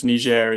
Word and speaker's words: Niger: I'll be using the French pronunciation, as Niger is part of the Francophone West African community.